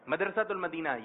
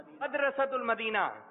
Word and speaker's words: مدرسۃ المدینہ: مدرسۃ المدینہ یہ کہہ رہے ہیں آپ نہیں یہ درست تلفظ نہیں ہے آپ بتائیے ایسی بتائیں ایسی بتائیں مدرسۃ المدینہ درست تلفظ بتا